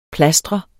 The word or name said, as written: plastre